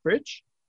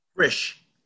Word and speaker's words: Frisch: Frisch? How do you pronounce that? F-R-I-S-C-H. Frisch? – Frisch. – Frisch, okay.